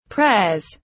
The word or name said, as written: prayers